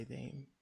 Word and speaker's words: Daim: Go to their cafeteria area and get the chocolate called Dame. It's spelled D-A-I-M, and it's fun just to say Dame like that.